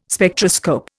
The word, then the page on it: Spectroscope: Indian